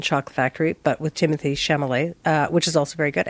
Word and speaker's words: Chalamet: Well, it's Chalamet.